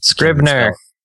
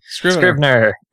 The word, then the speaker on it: Scrivener: It's called Scrivenger. I'll have the link in the show notes because I don't even think I'm pronouncing it right. And Scrivener. – Yes, that thing. – Scrivener.